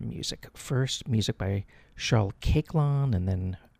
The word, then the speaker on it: Koechlin: First, we're going to hear music by Charles Caiclin, that's spelled K-O-E-C-H-L-I-N, Caiclin.